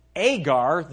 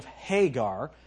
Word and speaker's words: Hagar: And then when it says that Agar, that would be a Greek pronunciation of Hagar.